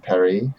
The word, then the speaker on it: Paris: I did hear today that it's pronounced Emily in Perry. – Are you serious? – Oh, Netflix tweeted that, so it's official. Mm. That way it rhymes.